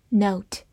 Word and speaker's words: note: Note, N-O-T-E, note，字母 O 发它本身的音 O，末尾的 E 不发音。